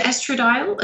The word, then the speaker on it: estradiol: Is it estradiol? – Estradiol, yeah. – Estradiol, yeah.